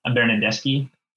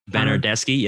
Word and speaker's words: Bernardeschi: So it went, if I'm seeing this correctly, it went Berardi, Belotti, Bonucci, Bernardeschi. Is that how you pronounce it? – Bernardeschi. – Bernardeschi, yep.